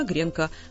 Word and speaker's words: гренка: Это и учел словарь ударений, который вышел в 2000 году. Там никакие гренки уже и не упоминаются, есть там только гренки. Соответственно, в единственном числе она гренка.